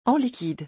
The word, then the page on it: en liquide: French